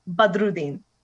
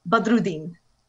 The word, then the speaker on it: Badruddin: And the last name is Badruddin. You have to roll. – Badruddin. – Close. Make the last syllable E. And you're almost there. Badruddin.